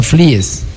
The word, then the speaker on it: Flies: No es Fly. Pero Fly es como checoslovaco por allá. Sí. – Pero se pronuncia Flies.